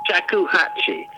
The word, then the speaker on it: shakuhachi: And we're closing with Frank Denyer, music for Shakuhachi. How do you say that? – Shakuhachi.